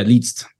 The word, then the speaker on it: Liszt: L-A-S-Z-T. Is it just List? How do you say that fucker's name? – Yeah, Liszt. Franz Liszt.